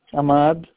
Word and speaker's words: Ahmad: Ahmad.